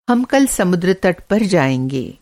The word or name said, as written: समुद्र तट